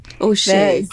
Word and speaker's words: O'Shea: Carol Mathias O'Shea. – It's like the French.